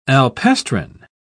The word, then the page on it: alpestrine: British English